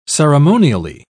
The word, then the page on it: ceremonially: British English